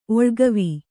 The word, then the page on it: ಒಳಗಿವಿ: oḷagivi